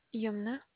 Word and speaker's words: Yomna: Yumna. – Can you spell that? – Y-O-M-N-A. – Y-O-M-N-A.